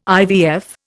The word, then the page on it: IVF: Indian